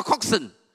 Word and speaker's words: coxswain: He's pronounced C-O-X, C-O-X-S-W-A-I-N. It's pronounced coxswain, not coxswain.